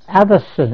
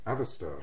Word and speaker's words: Atherstone: Addison in Warwickshire. – How do you spell it? – Addison, A-T-H-E-R-S-T-O-N-E. – Addison.